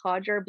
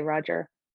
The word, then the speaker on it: Rodger: In Brazil, I believe it's pronounced Roger, because of the first letter in Portuguese, it's pronounced as H. For people in maybe in England that struggle to pronounce it, it's easier to just say Roger.